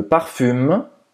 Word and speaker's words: parfum: Je me parfume. Je mets, eu coloco, parfume. Je mets du parfum. Hein? Parfum, c'est escrevi parfume, mas se pronuncia parfum. Parfum, bem nasal.